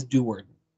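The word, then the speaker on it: Do'Urden: The last name is Deward.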